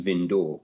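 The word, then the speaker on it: Vindö: And Vindu Marin actually is a fascinating place because the classic archetypal Swedish archipelago boat is called a Vindu, V-I-N-D-O, and it's got the two little dots over the O, so I think it's pronounced Vindor.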